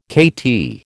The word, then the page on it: KT: American English